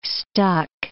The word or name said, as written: stuck